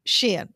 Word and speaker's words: Shein: Shein.